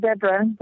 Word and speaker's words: zebra: So technically, that's the proper way to say the word, but Americans say zebra instead, but they're actually zebra.